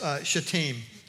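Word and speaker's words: Shittim: It's actually pronounced Shatim, you know. Yeah, at least that's the way the Baptists pronounce it. And but it is. I think that's the actual pronunciation of it.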